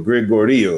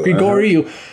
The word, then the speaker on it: Grigoriou: And as you brought up earlier, Father, the point of concern was that quote from Father George, and I can't pronounce Gregorio. How do you say that, Father? – Gregorio. – Gregorio.